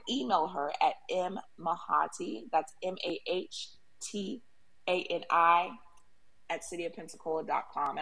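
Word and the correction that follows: Mahtani: And I pronounced that wrong. It's Matani.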